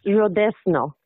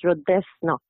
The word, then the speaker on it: Redesno: It's Redesno. – Redesno. – Most of the people pronounce it, they put the N before the Z, but it's the Z before the N, so it's Redesno.